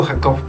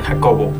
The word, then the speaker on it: Jacob: De repente no digo Jacob porque la gente tiende a poner Jacobo, que es otro nombre. Pero solamente digo Jacob porque se entiende más como se escribe.